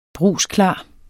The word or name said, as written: brugsklar